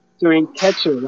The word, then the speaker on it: Souren Khetcho: Souren Khetcho, right? – That's correct.